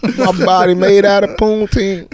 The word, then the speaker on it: poutine: Poutine? – Poutine.